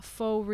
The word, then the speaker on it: Faux: We called ourselves Faux Real, Faux spelled F-A-U-X.